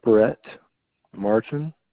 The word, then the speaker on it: Brett Martin: Brett Martin.